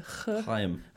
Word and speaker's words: Chaim: I think it's like a Chaim.